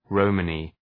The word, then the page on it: Romany: ‘rɒmənı